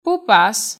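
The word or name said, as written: Πού πας;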